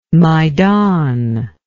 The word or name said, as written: maidan